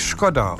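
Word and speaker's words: Škoda: Deswegen, der Skoda wird ja eigentlich Skoda ausgesprochen.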